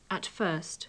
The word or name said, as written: at first